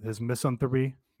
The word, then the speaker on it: misanthropy: misanthropy. Briefly. I discussed it on the Patreon, I think. Fuck, how do you say that word? – Misanthropy. – Misanthrope. Okay.